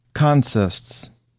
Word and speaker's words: consists: Consists. Accented on the first syllable, consists is a railroad term for what a train is carrying.